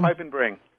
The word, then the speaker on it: Piepenbring: was I close in pronouncing his Pretty name, good, yeah, Tom? Piepenbring. – Perfect.